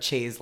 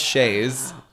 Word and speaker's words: chaise: One time I wanted a zebra chaise lounge. – Some things never change. First of all, it's pronounced chaise, not chaise.